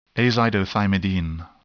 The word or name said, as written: azidothymidine